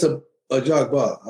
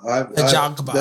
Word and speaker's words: Ajagba: And I know that did you say Ajabwa or Ajaba? Ajaba, right? – Well, I've said it's Ajagba. – Ajagba. – That's how I pronounce it.